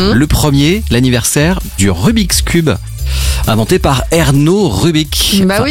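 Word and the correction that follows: Ernő: Erneux d'ailleurs, c'est comme ça qu'on devrait le prononcer.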